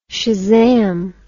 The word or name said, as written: shazam